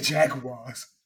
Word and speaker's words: Jaguars: The Jaguars. – Yes, because they are in London, so I will pronounce it correctly and call them the Jaguars.